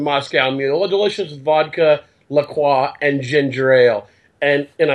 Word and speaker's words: La Croix: The Publix had La Croix. Yes, that is how you say it.